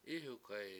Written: Ihukhayi

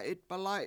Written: 7itbalAy7

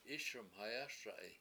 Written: IIS1mhayASa7i

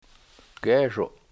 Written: geeshu7

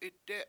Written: itdE7

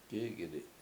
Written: k7Ik7idi7

